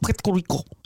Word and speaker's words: Puerto Rico: Why are you saying it like that? Puerto Rico. – That's the way the locals say it. Puerto Rico.